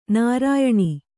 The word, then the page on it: ನಾರಾಯಣ: nārāyaṇa